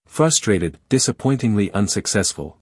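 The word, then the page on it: frustrated: uk frʌˈstreɪtɪd; en-us ˈfrʌstreɪtɪd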